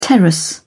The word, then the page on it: terrace: British English